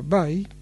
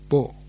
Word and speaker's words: Bai: Su nombre se pronuncia Bai, que es mandarín moderno, pero en el pasado tenía una pronunciación alternativa, que era Bo, Li Bo. Esta misma además se transcribió como Li Po.